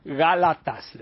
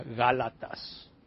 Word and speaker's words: Gálatas: Y luego le dijo el pastor, Gálatas, le dijo así con la voz que tenía, Gálatas.